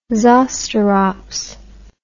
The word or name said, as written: zosterops